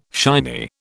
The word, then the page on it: shiny: British English